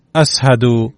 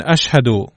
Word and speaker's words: اشهد: فكان بلال يقول عند الاذان اسهد بدلا من اشهد